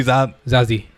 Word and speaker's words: Zazie: And you said Zaza. Her name actually Zazie. Is her name? Her name is pronounced Bates. Zazie Bates.